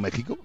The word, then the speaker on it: México: Ellos lo ponen con X y decimos México. ¿O no has escuchado el Israel? – Claro que sí. – Pues ya está, se escribe con X y se dice con J.